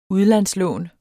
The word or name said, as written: udlandslån